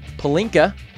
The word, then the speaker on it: Pelinka: Former Cleveland Cavaliers coach Tyronn Lue is meeting with the Lakers general manager Rob Pelinka. I'm going to pause and make sure that's the right way to say it. – Yep. – Okay. – You nailed it.